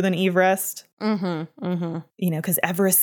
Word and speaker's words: Everest: His name was Sir George Everest. Not Everest. So we've been pronouncing his name wrong this entire time.